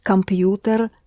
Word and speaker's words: компьютер: Инженер может починить компьютер. – It's pronounced just like the English compute.